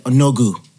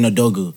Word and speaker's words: Odogwu: Let's say I said Onogu instead of saying Odogwu.